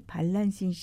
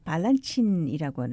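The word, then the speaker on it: Balanchine: 조지 발란신 씨를 만나게 된 겁니다. – 네. – 러시아식 발음으로는 이 게으르게 발란친이라고 하는데요.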